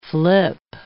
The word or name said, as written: flip